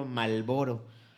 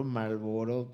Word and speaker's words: Marlboro: Malboro es que tiene una R entre la A y la L, ¿no? ¿Cómo se pronuncia Marlboro? Marlboro.